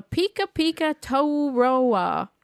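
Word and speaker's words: pekapeka-tou-roa: The Pika Pika Tauroa. That's how it's said.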